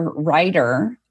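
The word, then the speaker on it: Riter: But if you search Heather Ryder and the word anxiety or Heather Ryder and imposter syndrome, you'll find me all over the place.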